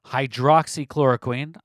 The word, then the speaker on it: hydroxychloroquine: It should be easy to go and seek out the studies of people using hydroxychloroquine. I know I pronounced it hydrochloroquine earlier. We're going to now pronounce it correctly.